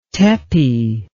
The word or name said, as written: tepe